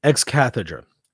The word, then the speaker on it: ex cathedra: I've been corrected on my pronunciation. I'm not a huge fan of that way of pronouncing this Greek term, but we'll go with it. Ex cathedra act that asserts Moses was not the author, and the faith does not require us to believe that he was.